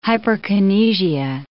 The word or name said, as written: hyperkinesia